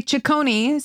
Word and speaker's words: Cecconi: Oh, I wanted to spell it C-E-C-C-O-N-I. – That's my last name, but no one knows how to say Chaconi. So I made my thing Chacon.